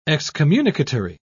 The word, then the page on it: excommunicatory: British English